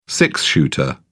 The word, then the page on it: six-shooter: British English